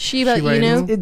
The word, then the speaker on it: Shiba Inu: Shiba Inu.